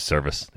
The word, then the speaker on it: Servais: Scott Servais, is that how you say it? Service? – Yeah, it's a service. Yep. – Service. Sorry, it's spelled Servais, but service.